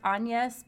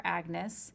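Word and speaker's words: Agnès: Whose name in French is pronounced Agnes, but because I'm lazy, we're calling her Agnes.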